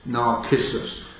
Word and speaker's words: Narcissus: Greet them which are of the household of Narcissus, we're giving that a hard C as well, which are in the Lord. Those from amongst Narcissus.